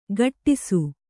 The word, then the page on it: ಘಟ್ಟಿಸು: ghattisu